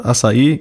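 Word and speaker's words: Açaí: Die kommt nämlich von Sari, die sagt, das Wort Acai stammt aus dem Brasilianischen, wo die Betonung auf dem I liegt. Die korrekte Aussprache hatte Felix, da das Wort Acai korrekt lauten muss.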